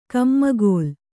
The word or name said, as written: ಕಮ್ಮಗೋಲ್